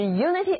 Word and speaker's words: unity: 这个时候因为变成了闭音节，所以字母 i 又回到它原来的读音，叫做短的 e，unity。画中色彩的统一就是 unity of color in a picture.